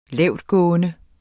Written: lavtgående

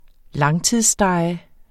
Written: langtidsstege

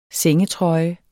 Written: sengetrøje

